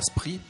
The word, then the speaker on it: Spree: Un endroit superbe avec une vue imprenable sur la Spree. On prononce ça comme ça, la rivière qui traverse la Spree.